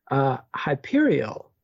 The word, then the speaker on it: Hyperreal: And then he sets up a whole server, which he calls Hyperial, spelled Hyperreal, but it's pronounced like Hyperion, Hyperial. I think I'm doing that right.